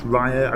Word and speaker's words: Raya: Spelt Raya, it's pronounced Raya. I don't know if that's an Americanism or, yeah.